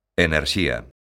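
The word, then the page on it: enerxía: Galician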